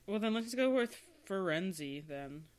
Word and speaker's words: Firenze: It's a city in Italy, which is pronounced Firenze, which is how I've always said it. If you ask the internet how to say it, like the word itself, because it's a real word, it says Firenze.